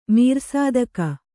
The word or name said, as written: ಮೀರ್‍ಸಾದಕ